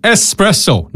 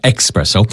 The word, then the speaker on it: espresso: Espresso, not expresso.